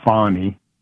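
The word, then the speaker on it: Fani: Yeah, Fannie Willis. She pronounces it Fonny, and so does her father, because she had daddy testify yesterday also.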